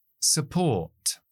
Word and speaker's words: support: Support. Support. Esa U es una schwa. Acentuamos port, la sílaba que empieza por la P.